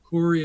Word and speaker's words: Coury: Coury is spelled C-O-U-R-Y.